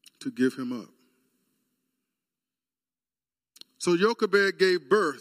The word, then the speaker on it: Jochebed: Jochebed is how you pronounce her name.